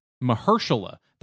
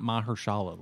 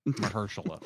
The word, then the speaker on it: Mahershala: Mahershala. That's how you pronounce his name. It's not Mahershala like we thought. Mahershala.